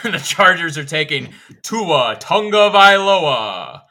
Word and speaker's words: Tagovailoa: It is tongue as in the appendage in your mouth, Tagovailoa.